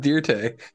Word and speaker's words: Dirt: I love that they all have the Joe Dirt. Like white trash facial hair, the fucking soul patches and shit. – It's pronounced Dirte. Don't church that name up, boy.